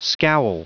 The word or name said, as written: scowl